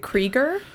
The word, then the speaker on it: Krieger: Henry, is it Krieger? – Yeah, Krieger and Tom Ian.